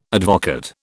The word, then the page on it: advocaat: British English